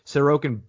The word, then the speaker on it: Sorokin: Ilya Sorokin. He's here. Obviously, he's an RFA after this season. He's making $2 million. Varley's going to get the start in game one against the Rangers. Sorokin better be in the pipes for game two.